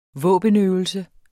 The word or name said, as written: våbenøvelse